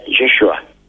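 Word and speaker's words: Yeshua (ישוע): En hebreo se pronuncia Yeshua.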